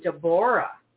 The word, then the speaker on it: Deborah: And the truth is I'm actually Deborah, but my parents didn't really. – Nobody pronounces it that way.